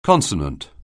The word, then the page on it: consonant: anglais